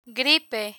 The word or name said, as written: gripe